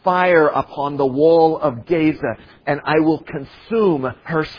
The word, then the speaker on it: Gaza: Verse 6, for three transgressions of Gaza, I'm sorry, not Gaza, Gaza is how you pronounce it.